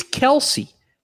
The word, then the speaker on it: Kelce: And in the Chiefs team guide, it's Kelce. That's the official pronunciation in the team guide. – Then it is Travis Kelce.